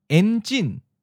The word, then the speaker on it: enjin: Enjin. En is in the ninth tone, and jin is in the second tone, high falling.